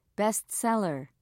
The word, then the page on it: best seller: British English